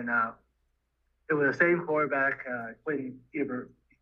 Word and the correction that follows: Ewers: I can't pronounce the last name. – But, Quinn uh, Ewers, I believe. Ewers, yeah, and uh, we won the game at 27 to 20.